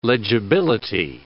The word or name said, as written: legibility